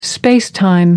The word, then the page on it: space-time: American English